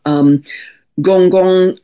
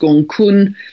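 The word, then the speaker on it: Gonggong: On the same theme of flooding, we have Gong Gong, a dwarf planet here. I think it's pronounced Gong Kun, at almost 6 degrees of Pisces, conjunct the North Node.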